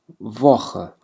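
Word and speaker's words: Woche: عنا أول شيء كلمة مهمة دي ووخ. دي ووخ يعني الأسبوع، كلمة الأسبوع. دي ووخ، التساء والها هنا تلفظ مثل حرف الخاء في اللغة العربية.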